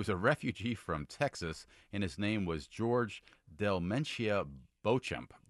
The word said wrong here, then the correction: Beauchamp: Oh, actually, it's pronounced Beecham, which is weird. – Beecham. – Because okay. – Because it's spelled like the French Beauchamp, so you think.